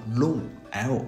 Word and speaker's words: loong: 其实有现成例子的，龙的英文音译啊叫 long，l o o n g，写的时候是两个 o，你读的时候甚至你都读成拼音的龙，很多老外都听得懂。美国功夫巨星李小龙，大家知道吧？一般人知道他叫 Bruce Lee，但其实他的全名叫 Bruce Lee Siu Long，就是这个龙。